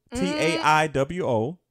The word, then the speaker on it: Taiwo: T A I W O. Taiwo. The last name is. Don't make me do this. It's spelled S-O-B-A-M-O-W-O.